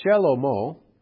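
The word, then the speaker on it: Shelomo (שלמה): And of course, David's son, Solomon, which is pronounced Shelomo, the N is completely off the end.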